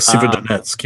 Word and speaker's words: Severodonetsk: There have been attacks on, I'm going to butcher this town's or this region's name, Severodonetsk. – Well, Severodonetsk, yeah. – Severodonetsk, thank you.